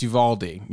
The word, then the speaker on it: Uvalde: Yes, it's Uvalde.